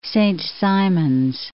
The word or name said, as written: Saint Simons Island